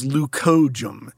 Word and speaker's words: leucojum: It's called a snowflake, its botanical name is leucojum.